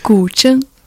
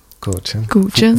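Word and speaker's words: guzheng: C'est Gu Zhen. – Gu Zhen.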